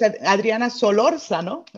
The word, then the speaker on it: Adriana Solarzano: Am I saying your name right? – That is correct. It's Adriana Solarzano. – Solarzano. – Right. – Gotta roll my R.